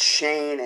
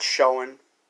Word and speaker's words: Schoen: Are you sure it's Shane and not Schoen? – No, it's Shane, because according to the pronunciation, Jordan Renan, it's actually pronounced Shane. That's how he pronounced it. It's Shane.